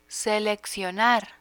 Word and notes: seleccionar: spanish

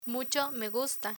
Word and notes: mucho me gusta: Spanish

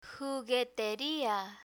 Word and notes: jugueteria: Spanish

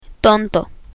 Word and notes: tonto: spanish

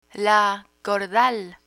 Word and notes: cordal: Spanish

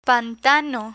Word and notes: pantano: Spanish

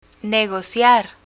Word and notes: negociar: Spanish

